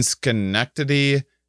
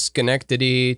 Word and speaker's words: Schenectady: So actually Willow, she asked to plug the MOPCO, MOPCO Improv Theater in Schenectady, New York.